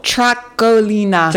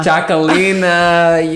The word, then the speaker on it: Txakolina: Tracolina. – Tracolina. Yeah, baby. But no one would know that it's pronounced that way just from the look of it.